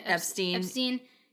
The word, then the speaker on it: Epstein: We've been angry all day because Epstein, is that how you say it? – Epstein killed himself.